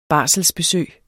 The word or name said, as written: barsler